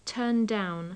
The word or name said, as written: turn down